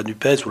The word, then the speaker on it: NUPES: la NUPES ou la NUP, selon comme on le prononce, a permis au Parti socialiste de continuer à exister à l'Assemblée nationale.